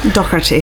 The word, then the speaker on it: Docherty: Docherty.